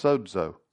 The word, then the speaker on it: sozo: It's the Greek word sozo, or more correctly pronounced, sozo. S-O-Z-O.